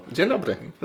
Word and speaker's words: Dzień dobry: Dzień dobry. Das ist sogar sehr gut ausgesprochen.